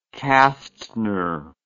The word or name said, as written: Castner